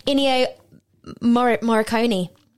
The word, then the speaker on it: Ennio Morricone: That's by Ennio Morricone.